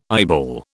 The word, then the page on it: eyeball: British English